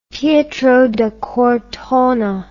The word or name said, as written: Pietro da Cortona